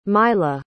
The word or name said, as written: mylah